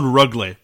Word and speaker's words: Rögle: And I think it's called Rögle, by the way. Rögle is how you pronounce the team.